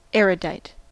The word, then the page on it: erudite: American English